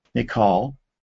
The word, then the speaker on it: Michal: And then we learn that in verse 20, Saul's daughter, Michal, there are several different pronunciations for it, but that's sort of the traditional English version. Saul's daughter, Michal, fell in love with David.